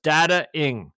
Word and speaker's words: Dataing: We're thinking it was about 6 months ago, and Eric is the president and founder of a new dating app called, I may not pronounce it right, but it's, I'll spell it, D-A-T-A-I-N-G, right? Yes. Dating? – Yes, okay. Sir. – It's Dating. So it's Dating, but it's AI-based.